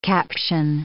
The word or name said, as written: caption